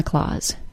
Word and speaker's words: Claus: The vowel sound in Santa's last name, Claus, is pronounced with the A-W sound, aw. C-L-A-U-S-E and C-L-A-W-S, like a cat has, are homophones and are pronounced exactly the same.